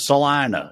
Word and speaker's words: Salina: Almost universally pronounced Selena everywhere else, but in my hometown. is called Salina. It's not just that everyone says it wrong. It's like that's how that that iteration of that town with that name, that's how it's pronounced.